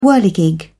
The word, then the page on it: whirligig: British English